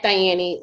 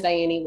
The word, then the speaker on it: Dhayany: At and then D-H-A-Y-A-N-Y. Most people ask, how do you say that? So think of the word die, like kill, die, and then Annie. So D-H-A-Y-A-N-Y. So that's on Instagram and all of my platforms. Everything is Diane, website, all of it is Diane.